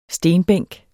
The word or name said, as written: stenbænk